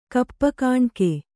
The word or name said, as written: ಕಪ್ಪುಗಾಣಿಕೆ